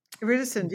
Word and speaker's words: iridescent: How do you say it in English? Iridescent, is that the right pronunciation? – Iridescent, yeah, I think so.